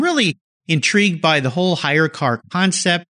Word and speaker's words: HyreCar: and why people need to learn about Hire Car. And I'll spell that for you listeners, HYRECAR, Hire.